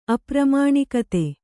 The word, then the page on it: ಅಪ್ರಮಾಣಿಕತೆ: apramāṇikate